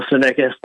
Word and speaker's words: Savickas: It's my full name, Daniel Savickas. The last name is spelled S-A-V-I-C-K-A-S.